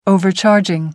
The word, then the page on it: overcharging: American English